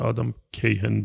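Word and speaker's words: Kahane: منم نمیدونم، این پنجشنبه جمعه یه کتابی رسید به دستم از یه آقایی به اسم آدم کاهان یا آدم کیهن به تلفظ خودشون، یکی از تحصیلگرهای فسیلیتیتورهای برجسته است در دنیا.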